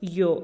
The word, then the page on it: yoo: TdVZ